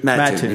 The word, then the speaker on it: Mattoon: Mattoon. You pronounce both T's.